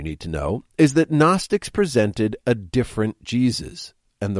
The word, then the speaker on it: Gnostics: They called themselves Gnostics, spelled with a silent G, like Gary Gnu.